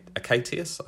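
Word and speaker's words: Achatius: the album is called Acat- Acatius. I think that's how you say it. Um, it is.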